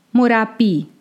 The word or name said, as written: Morabbī